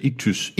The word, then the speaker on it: Ichthys: I-C-H-T-H-Y-S. Ictus, Ictus sapiens. Okay. Ictus sapiens, fiskemenneske, græsk. Bogstaverne tillægges således I, C-H. Ristos, e Eo og H-Y-I-os. S. Oter. Jesus Kristus, Guds søns frelseren. Iktys er et tidligt kristent symbol, der afbildes som et anker, et korssymbol.